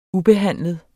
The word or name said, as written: ubehandlet